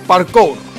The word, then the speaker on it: Parkour: Hay una cosa ahora que se llama lo del Parcoir o algo así, o Parcoir, se escribe P-A-R-K-O-U-R, Parcoir, ¿vale?